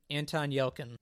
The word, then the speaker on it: Yelchin: But my question for you on Green Room is how was Anton Yelkin? Is that how you say his name? Yeah, Anton Yelkin.